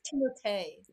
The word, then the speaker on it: Timothée: Because he's Timothy? – There's like an accent on the E at the end of his name. – I'm pretty sure it's Timothée.